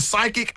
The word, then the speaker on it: psychic: I know you're so you're right, because when we see psychic, it's spelled P-S-Y, right? – I couldn't tell you. – Yes, yeah. – So the P is silent.